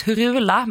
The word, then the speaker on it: Hurula: Hurula. Jag har alltid sagt Hurula, men jag har idag lärt mig att det uttalas Hurula.